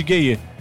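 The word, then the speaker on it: Gueye: Por sinal, os gayê da seleção de Senegal, na real, a pronúncia correta é gay.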